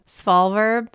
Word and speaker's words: Svalbard: How do you say that? S-V-A-L-B-A-R-D? – Svalbard.